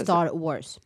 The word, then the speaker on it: Star Wars: Uttalas Star Wars.